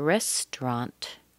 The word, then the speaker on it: restaurant: At how the restaurant, restaurant, restaurant. We're missing a syllable here, aren't we? There's no A-U. Restaurant. It sounds like R-E-S-T-R-A-N-T. Restaurant.